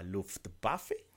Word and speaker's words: Luftwaffe: Y se enfrentan a un equipo que era base de la Luftwaffe, ¿se dice así? – Sí, los...